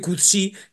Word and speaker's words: Coetzee: Coetzee, Coetzee, vamos a llamarle Coetzee, que de todo lo que yo he rastreado por ahí parece que es el modo de pronunciar, hay quien dice Coetzee, Kutsi, en definitiva, J.M.